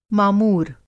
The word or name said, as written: मामूर